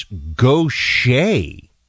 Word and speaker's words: Gautier: How to pronounce Gautier, it's Gautier.